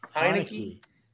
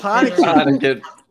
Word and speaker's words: Heinicke: How do you say it, then? – Heinicke. Yeah. – Taylor Heinicke. All right. – It's Heinicke.